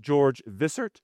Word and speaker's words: Wishart: Patrick Hamilton and George Wishart, probably pronounced George Vissert. So I'll try to keep with his beginning pronunciation going forward, but it's spelled Wishart.